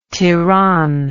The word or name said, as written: Tiran